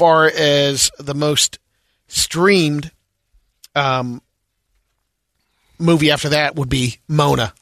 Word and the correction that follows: Moana: like I say, Moana.